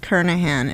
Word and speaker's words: Kernighan: It's pronounced Kernahan. It's spelled K-E-R-N-I-G-H-A-N.